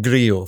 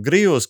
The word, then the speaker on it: Grillo: As the three of you surely know, after countless paellas, pollo sandwiches, and watching Seve Ballesteros, the double L in Spain is pronounced as a Y, not as an L. The US TV announcer, says Marcos, insists on calling him Grillo.